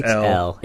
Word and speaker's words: Elle: So, question: Is it Elle Fanning or Ellie Fanning? – It's L. – It is L. Oh, I've been saying that wrong my whole life.